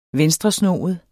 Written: venstresnoet

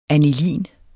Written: anilin

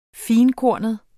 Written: finkornet

